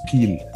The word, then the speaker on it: Peel: It's a long E, it's Peel.